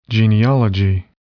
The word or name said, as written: genealogy